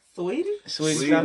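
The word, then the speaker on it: Saweetie: What's her name? – Sweetie. – But it's spelled like Sautwee.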